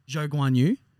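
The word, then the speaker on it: Zhou Guanyu: Yes, it's actually pronounced Zhou Guan Yu. – Zhou Guan Yu. – I'm he confirmed it on a podcast the other week or like in an interview or something. It's Zhou Guan Yu, so we need to change that up a bit.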